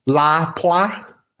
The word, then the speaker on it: Lapua: Lapua. yeah, I believe that's the proper way to say it.